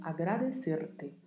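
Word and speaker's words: agradecerte: Bastante atenção com a pronúncia, porque temos o vibrato da letra R e temos o T ao final, que não é pronunciado ti, é pronunciado T. Então, agradecer-te por,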